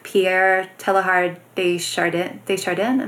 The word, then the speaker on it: Pierre Teilhard de Chardin: I just found out recently that the story is actually a refutation of Pierre Teilhard de Chardin. Am I saying that right? Mm-hmm. Yeah.